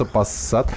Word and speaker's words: Passat: Ну, в слове Passat тоже две буквы S. Ну да, и она, они произносятся Passat.